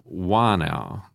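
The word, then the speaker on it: whānau: So, for example, the word uh, whanau uh, is spelt with an H, a WH, but it is pronounced up the river Wanau. There is no H.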